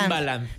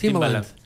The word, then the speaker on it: Timbaland: ¿Pero cómo se llama? Timberland. – Timbaland. – Lo dijeron acá, Jaime Alvarado lo dijo. – Es Timbaland.